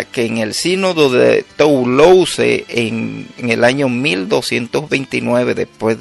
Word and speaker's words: Toulouse: Puedes buscar eso en internet, anótalo allí, Sínodo de Toulouse, así como se pronuncia, usa la S en lugar de la C, Toulouse.